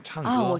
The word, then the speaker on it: 唱歌: 창 너무 꺼 하지 마시고 우리 선생님처럼 힘을 빼시고. – 唱歌。 – 예, 쌍기역보다는 좀 기역과 쌍기역의 느낌인 것 같아요. 가운데 느낌, 창거.